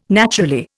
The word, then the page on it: naturally: Indian